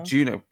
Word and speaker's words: Juneau: Juneau. – Juneau.